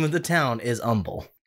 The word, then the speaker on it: Humble: In humble... Okay, listen, I need... First off... I'm going to help all of you. Okay? The name of this town is Humble. The H is silent. – Spelled H-U-M-B-L-E.